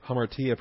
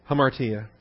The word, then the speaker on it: hamartia: It says the general term for sin is, I'm just wondering how you pronounce that. – Hamartia, probably, on 255. Hamartia. – Okay. – Hamartia, meaning to miss the mark.